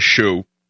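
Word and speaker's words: Hsu: Now, it's normally you would think it's spelled S-H-O-E hyphen gate, but it's H-S-U gate.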